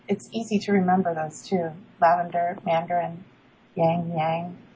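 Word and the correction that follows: ylang-ylang: How do you say that again? – Ylang ylang. Yes.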